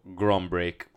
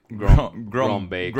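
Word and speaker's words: Grønbæk: That's on Serie A Spotlight. That's the correct pronunciation, bro. Grombeek. – Grombeek.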